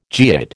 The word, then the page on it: geoid: American English